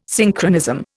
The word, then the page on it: Synchronism: Indian